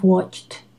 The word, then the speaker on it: watched: He doesn't pronounce the D, the dental, in watched. You need to pronounce the T here, okay? So, I don't know if you have watched Glee.